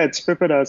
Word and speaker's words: PIPEDA: Yeah, it's PIPEDA. So it's PIPEDA.